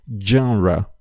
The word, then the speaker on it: genre: Genre é, é uma palavra que normalmente acaba sendo pronunciada de forma incorreta, Uh, mas saibam que, por vir do francês, a gente tem que também utilizar a pronúncia do francês.